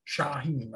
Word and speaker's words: Shaahin: Shaahin.